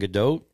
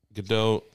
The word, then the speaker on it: Gadot: Affleck, Gadot. Is it Gadot or Gadot? – Gadot. – Gadot. – It's you do pronounce the T.